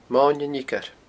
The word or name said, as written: Moine an Fhiocair